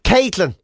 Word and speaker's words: Kviiilyn: K-V-I. What was the end bit? – K-V-I-I-I-L-Y-N. – I have no idea, man. – Caitlin. Because they've used the Roman numeral for 8 in the middle of the name. – Oh, for God's sake. – To make it Caitlin.